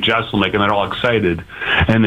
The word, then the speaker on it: Jeselnik: On the Celebrity Hotline right now with Anthony Jeselnik, stand-up comedian coming to Milwaukee this weekend.